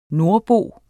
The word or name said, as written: NORDBOEN